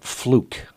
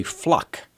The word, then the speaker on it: Fluke: It's not Fluke. You would think F-L-U-K-E would be Fluke. In the English I learned, the E normally means a long U, does it not? – That's how I was taught. – Maybe they don't teach it like that anymore. But her name is actually Fluck, F-L-U-K-E, but they don't pronounce the long U. It's Sandra Fluck.